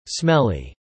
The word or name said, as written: smelly